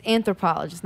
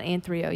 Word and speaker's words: anthropologist: It's anthropologist, not anthrio. You actually added a syllable, which is impressive.